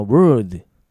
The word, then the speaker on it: world: そしてもう一個は World, world。簡単に聞き分けられるね。よろしいです。自分で発音してごらん。 World。難しいよーっていう人のために発音の解説いきます。 World をね、これ発音単語見てね。 OR の部分でベロ巻いてんだぞ。